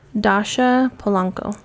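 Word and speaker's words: Dascha Polanco: Dasha Polanco.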